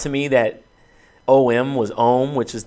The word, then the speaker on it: OM: That's why I pronounce it that way and not just OM as an acronym because it sounds better. I mean, so from a branding perspective, it sounds better to say OHM than it does to say OM.